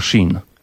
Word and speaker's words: Sheen: Wiktor Schin. – Wiktor Schin. A jak się pisze to Schin? – Wiktor, przez... pisze się przez K, a Schin tak z angielska S-H-E-E-N.